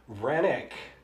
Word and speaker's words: Roenigk: I've been racking my brain on how to pronounce this ever since I did the research. R-O-E-N-I-G-K.